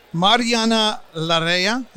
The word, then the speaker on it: Mariana Larrea: So we have Mariana Larrea.